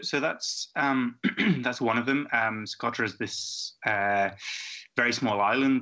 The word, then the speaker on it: Socotra: Socotra, Yemen.